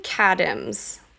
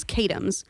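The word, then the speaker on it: Katims: So Jason Kadims, I think that's how his last name is pronounced. It's K-A-T-I-M-S.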